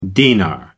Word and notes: dinar: American English

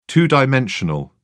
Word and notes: two-dimensional: British English

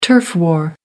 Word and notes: turf war: American English

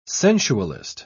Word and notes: sensualist: British English

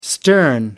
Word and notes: stern: American English

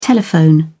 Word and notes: telephone: British English

